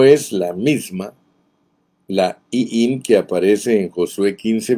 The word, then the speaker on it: Iim: Iim y Esem. Este Iim o Ijiim, como se pronunciaría en el hebreo,